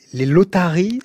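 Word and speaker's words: lăutari: Oui, alors on préfère aussi aujourd'hui ce que l'on appelle les lotari, c'est ça ? C'est comme ça qu'on prononce ? – Les lotari. Ben, en fait, c'était les lotari, celui qu'on a écouté, Dona Dumitru Siminik, était un lotar aussi. Ouais. Ce qu'on a entendu, ce sont des lotari aussi.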